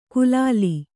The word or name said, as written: ಕುಲಾಲಿ